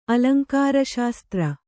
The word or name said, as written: ಅಲಂಕಾರಶಾಸ್ತ್ರ